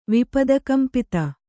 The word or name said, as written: ವಿಪದಕಂಪಿತ